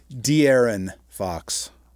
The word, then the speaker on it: De'Aaron Fox: You had predicted Sabonis as the top performer, but in fact it was DeAndre Fox, and I hope I'm getting pronouncing his name. – DeAndre. – De'Aaron Fox.